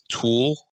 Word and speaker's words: tulle: Like, I was in Michael's craft store and I was getting tulle fabric, T-U-L-L, but it could also be pronounced tulle.